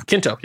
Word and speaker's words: Quinto: Kids, that's how you say it, right? – Oh, yeah. Quinto.